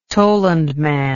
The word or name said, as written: tollund man